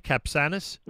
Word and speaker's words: Capçanes: Am I pronouncing that per- correctly? I hope so. Uh, is that right? Capçanes. That that is correct. It's spelled C A P C A N E S, and I would assume with great um, uh, with great certainty.